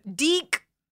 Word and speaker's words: DIC: So from Deke, We had a lot of people correct us, which we asked for. We were like, how do you say this? D-I-C, Deke Entertainment.